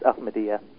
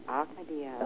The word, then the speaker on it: Ahmadiyya: Yeah, it's Ahmadiyya. – Ahmadiyya, okay. And, you know, I thought maybe, that would be a good place to start. Could you tell us a little bit about the Ahmadiyya Muslim community? – Yep, I can definitely do that.